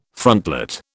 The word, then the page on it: frontlet: British English